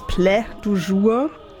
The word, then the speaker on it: Plat du jour: Das war jetzt musikalisch auch nicht mein Favorit heute, aber ich dachte an sein Konzeptalbum, es schreibt sich Plat du jour. Anscheinend spricht man es Plä du jour.